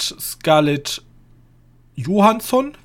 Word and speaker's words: Scarlett Johansson: Und mit Scarlett Johansson, wird sie ja eigentlich ausgesprochen,